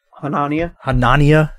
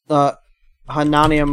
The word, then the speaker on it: Hanania: How do you say his last name? – Hanania. – What is it like? – Like more like Hanania, my interests. – Hanania.